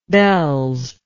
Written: bells

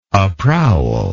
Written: aprowl

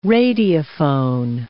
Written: radiophone

